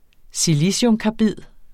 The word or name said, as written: siliciumkarbid